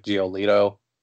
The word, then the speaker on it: Giolito: You know when when they got Moncada and uh, I forget how how to pronounce his name, but like Giolito, is that how you say yeah.